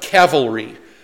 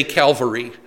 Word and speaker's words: cavalry: It's supposed to be pronounced cavalry, but most of us say Calvary.